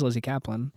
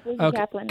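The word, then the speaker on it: Lizzy Caplan: And I love Lizzie Kaplan. I think that's, I'm saying her name right, aren't I? It is Lizzie Kaplan. – Yeah. Lizzie Kaplan.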